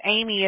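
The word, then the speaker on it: Amie: My name is Amy. It's A-M-I-E, and I hate it when people spell it wrong.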